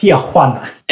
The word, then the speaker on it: Tijuana: It's pronounced Tijuana.